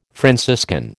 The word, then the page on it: Franciscan: American English